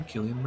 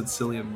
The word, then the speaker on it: Cillian: I didn't even know how to say his name before until I heard him say, I'm Cillian Murphy. I always said Cillian Murphy.